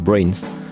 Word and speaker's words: BRNS: Alors ça s'écrit B-R-N-S et ça se prononce Brains.